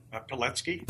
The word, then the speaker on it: Pilecki: Pilecki.